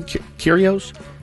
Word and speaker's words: Kyrgios: Kyrgios. – Tennis player, a Wimbledon finalist, Kyrgios.